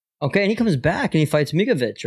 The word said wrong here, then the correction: Miocic: right? Not Mikovic. It's I can never say his name, right? How do you say it? – Who's Stipe? – Stipe, yeah. – Miocic.